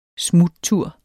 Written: smuttur

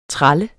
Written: TRALLER

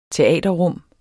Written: teaterrum

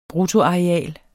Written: bruttoareal

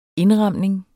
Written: INDRAMNINGER

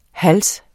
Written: HALSENES

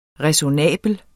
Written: ræsonnabel

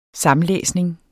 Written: samlæsning

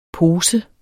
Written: pose

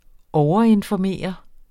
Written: overinformere

